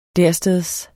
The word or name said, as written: dersteds